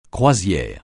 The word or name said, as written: croisière